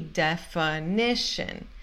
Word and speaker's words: definition: Aqui, definition. F-I tem som de F. N-I tem som de N.